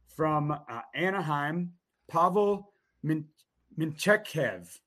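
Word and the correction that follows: Mintyukov: Close. Mintikov. – Mintikov.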